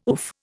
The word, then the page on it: oof: Indian